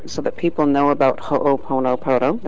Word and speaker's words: Ho'oponopono: We're going to talk to Dr. Lynn about Ho'oponopono. I hope I'm finally saying that right. – Perfect. – Well, yay. – Perfect. – I practiced all day, Dr. Lynn. – You must be a Hawaiian at heart.